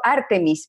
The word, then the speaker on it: Artemis: Es un artículo de Artemis, Lo, Artemis, perdón, que creo que es con acento en la primera sílaba, sílaba Artemis López,